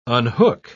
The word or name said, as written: unhook